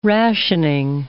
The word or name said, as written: rationing